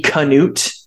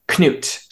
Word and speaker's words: Knut: But uh, anyway, so this is during the Viking Age, and the song is about a guy who the, the way it's written in English here is C A N U T E Canute. – It's mm-hmm. basically written phonetically. His name's actually Knut. C N U T is how it's actually written, or sometimes K N U T um, in like Old Norse.